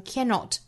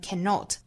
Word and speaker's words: cannot: 其实 cannot 这个词可以把重读放到后面，也可以放到前面读成 cannot 或者是读成 cannot，